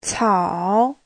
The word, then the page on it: 草: cao3